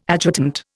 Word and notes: adjutant: Indian

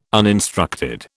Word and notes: uninstructed: British English

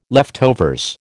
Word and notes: leftovers: American English

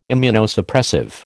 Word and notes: Immunosuppressive: American English

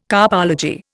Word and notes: carpology: Indian

